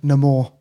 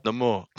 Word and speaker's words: Namor: You keep like on pronouncing it, his name wrong. – Sorry, Namor. – Namor. Namor.